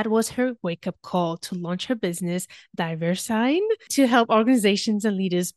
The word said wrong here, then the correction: DiverseIn: So first of all, DiverseSign, is that the right way to pronounce it? – We call it DiverseIn. It's a little bit short version of a little bit diversity and inclusion. Mm-hmm. So that is where it's coming from, DiverseIn.